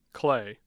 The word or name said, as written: clay